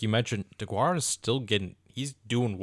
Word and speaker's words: Deguara: DeGuara. Josiah DeGuara.